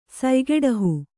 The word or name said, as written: ಸಯ್ಗೆಡಹು